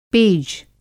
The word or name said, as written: पेज